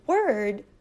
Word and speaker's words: word: Now the word word, we have no L sound. It is word, word. So let's listen to the difference. World, word, world, word.